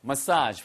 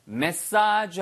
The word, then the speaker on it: massage: 발음 주의하세요, 이거. 메시지 그러면은 전달하는 메시지고요. 예, 그 다음에 전달 사항인 거죠. 얘는 massage예요. massage. 발음은 두 가지입니다. massage 하셔도 되고 massage 하셔도 돼요.